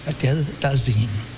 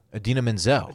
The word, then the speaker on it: Idina Menzel: please welcome the wickedly talented one and only Adele Dazeem.